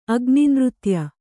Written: ಅಗ್ನಿನೃತ್ಯ

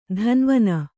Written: ಧಾನ್ವನ

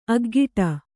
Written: ಅಗ್ಗಡ

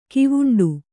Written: ಗವುಂಡಿ

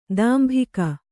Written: ತಂಬಾಕು

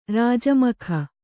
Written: ರಾಜಮಖ

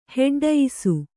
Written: ಹೆಡ್ಡಯಿಸು

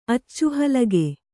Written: ಅಚ್ಚುಹಲಗೆ

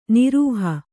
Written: ನಿರೀಹ

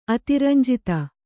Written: ಅತಿರಂಜಿತ

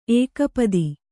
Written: ಏಕಪದಿ

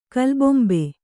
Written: ಕೀಲುಬೊಂಬೆ

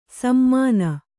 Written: ಸಮಾನ

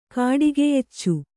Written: ಕಾಡಿಗೆಯೆಚ್ಚು

